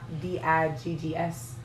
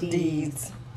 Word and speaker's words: Diggs: Because it's spelled like D I G G S. D's. Diggs? – No, it's, no, it's pronounced, pronounced Deeds.